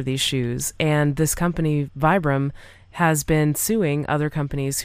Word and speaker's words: Vibram: And these are made by Vibram or Vibram, however it's pronounced. – I think it's Vibram. Yeah. – Yeah. – Like five fingers. – Yep. – Is a long I or whatever.